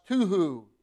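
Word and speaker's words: tohu: It's a Hebrew word that's pronounced tuhu.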